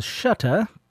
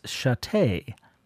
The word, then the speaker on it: Shutta: You may have heard me previously pronounce Ethel's name as Shutta, but this week I heard a recording of Ethel herself pronouncing her name, and it's Chatte.